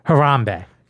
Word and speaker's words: Harambe: Harambe. – Yes, that's right. It's the first time you said it correctly today, and I'm proud of you.